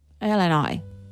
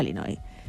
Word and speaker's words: Illinois: Illinois. No Illinois. Bien. Sigue, no sé si lo habéis escuchado, yo sí lo he escuchado. Dice que pronuncia Illinois.